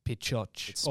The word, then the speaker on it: Pixote: How do you pronounce it? – Pichote. Or Pichote. – It's spelled what? – P-I-X-O-T-E. – Okay.